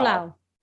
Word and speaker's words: pulau: How do you say Pulau. – It? – Yeah, mate. – Pulau. Pulau. – Yeah, P-U-L-A-U Pulau.